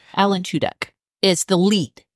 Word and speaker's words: Tudyk: Tuduk.